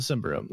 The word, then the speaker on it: Symbaroum: Our two running theories are Symbaroum or Symbaroum. But we're going to go with Symbaroum. – Yeah, we think Symbaroum wins because we went and we listened to the original announcement, which was not in English. And the syllables that we extracted were those. – It was pretty close to Symbaroum.